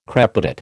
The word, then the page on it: crepitate: American English